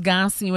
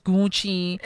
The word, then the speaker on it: Gucci: Who are reminding you, who are laughing at the way you pronounce Gansi when it's Gucci, who are laughing that you call it Hors d'oeuvres when it's actually hors d'oeuvres.